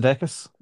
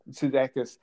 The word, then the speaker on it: Sudeikis: Jason Sudeikis. – Sudeikis, who is Ted Lasso.